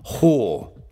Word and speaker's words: whore: Whore. E in realtà è uno di quei classici esempi dove è scritto in una maniera completamente stupida. È W, che non si pronuncia, W-H-O-R-E, ok? Ho.